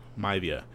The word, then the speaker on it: Maivia: His mother, Ada Johnson, is Samoan, and both of her parents, High Chief Peter Mieva. I always mispronounce this. – Maivia.